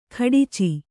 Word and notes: ಖಡಚಿ: kannada

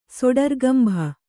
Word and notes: ಸೊಡರ್ಗಂಭ: soḍargambha

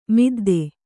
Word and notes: ಮಿದ್ದು: middu

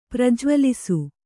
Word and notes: ಪ್ರಜ್ವಲಿಸು: prajvalisu

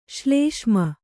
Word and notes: ಶ್ಲೇಷ್ಮ: ślēṣma

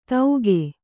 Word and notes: ತೌಗೆ: tauge

